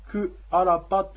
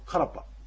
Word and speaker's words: カラッパ: これは行ってきたよというんですけども、カラッパというのは実は自分が行ってきたということで、クアラッパというのがこの猿川流域ではカラッパというふうに発音されます。